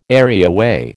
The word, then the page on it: areaway: American English